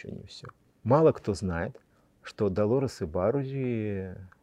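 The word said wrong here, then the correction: Ибаррури: чаще, кстати, правильнее будет именно и Барури, поскольку в испанском языке ударение идет на второй слог, вот.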